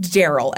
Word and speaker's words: Darrell: And Daryl is spelled D-A-R-R-E-L-L. – So it's Darrell. – It's pronounced Daryl. Everything pronounced. I thought it was Darrell, too. That's how I read it. – Uh-huh. – But all of the stuff I listen to pronounces it Daryl.